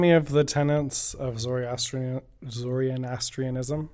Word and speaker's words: Zoroastrianism: So yeah, Nowruz has been going around for about 3000 years, and it started with the Zoroastrian Zoroastrianism, if I'm saying it right. – Zoroastrianism. – Yes, thank you.